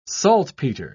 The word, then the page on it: saltpeter: British English